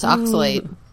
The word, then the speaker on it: oxalate: Do you know how to pronounce that? – Yes, Robin. It's oxalate.